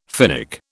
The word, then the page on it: Finnic: British English